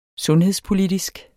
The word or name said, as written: sundhedspolitisk